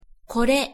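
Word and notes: kore: Japanese

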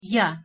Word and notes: я: Russian

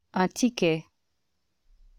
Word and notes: un ticket: French

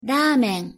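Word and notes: ramen: Japanese

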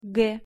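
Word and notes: г: Russian

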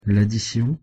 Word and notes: laddition: French

